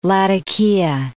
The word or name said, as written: Latakia